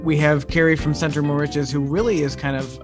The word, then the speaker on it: Moriches: Moriches. Remember, Moriches. The more riches, the more money.